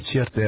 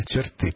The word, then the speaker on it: черты: Я вот хочу, я считаю, что все-таки правильное ударение делать не черты, а черты, потому черты. Что слово чертить, черта, черты, да?